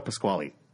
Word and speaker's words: Pasquale: how do you say Pasquale or Pasquale? – Pasquale. – We did it! We solved the mystery!